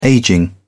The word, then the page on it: aging: British English